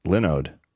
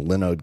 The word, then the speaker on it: Linode: Linode uh, lets you instantly deploy and manage SSD servers in the Linode cloud. I used to pronounce it Linode because that's what it looks like.